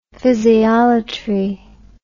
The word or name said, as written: physiolatry